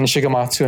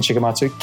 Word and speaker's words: Shigematsu: And then my Twitter handle is simply Ken Shigematsu, and Todd, as I mentioned, you did pronounce it right. So it's phonetic. It's at Ken Shigematsu, K-E-N, Kilo Echo November. And then Shigematsu is simply spelled S-H-I-G-E-M-A-T-S-U.